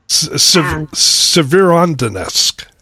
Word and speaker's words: Severodonetsk: Severodonetsk. – Severodonetsk.